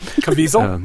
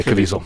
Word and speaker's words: Caviezel: Caviezel. – Yeah, Caviezel.